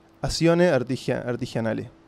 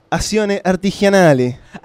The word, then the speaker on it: Azione Artigianale: Primero te pronuncio en uruguayo para que veas la diferencia. – Dale. – Uruguayo. Azione Artigianale. Así, aburrido. – Está. – Italiano. Azione Artigianale.